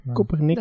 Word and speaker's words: Koppernik: Kopernik, hoe spreek je dat eigenlijk uit? – Zijn Kopernik.